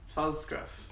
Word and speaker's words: Pfalzgraf: It is my friend Stephanie Pfalzgraf. How do I say that? Did I butcher your name again? – No, you didn't. – Almost? How do you say it correctly? – Pfalzgraf. – Pfalzgraf.